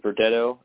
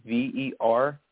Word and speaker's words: Verdetto: So my name is Leonard, LeoNARD, and Verdetto is spelled VERDETTO, the third.